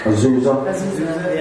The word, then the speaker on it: Azusa: but Azusa Pacific? – Azusa.